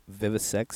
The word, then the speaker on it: Vivisect: And it's pronounced "vivisex 6.